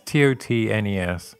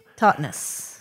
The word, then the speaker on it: Totnes: I think it's called Totnes. It's Totnes. – Totnes. – Yeah, Totnes.